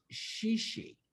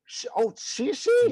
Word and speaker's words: Chi Chi: Now let's correct you, Vicky. It's pronounced Shishi. – Shishi? – Ooh. Yeah. – Have I been saying this wrong? – It's French, darling. It's French. Shishi LaRue. It means chic street.